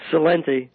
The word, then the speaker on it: Celente: Founder and director of the Trends Research Institute, Celente, if I'm pronouncing that right, is that correct, Gerald? – It's Celente.